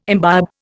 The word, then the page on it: imbibe: Indian